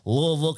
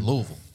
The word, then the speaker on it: Louisville: Or on the way to eight, moved to Louisville, Kentucky. – God dang, dude. – I didn't even say it right, dude. – Louisville.